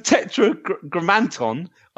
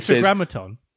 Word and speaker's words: Tetragrammaton: And the Tetragrammaton, I'm pronouncing that right, by the way. – The Tetragrammaton.